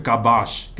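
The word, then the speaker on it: kabash (כבש): Esta palabra hebrea viene de una, palabra ah, que, que, se pronuncia kabash, que es la palabra hebrea.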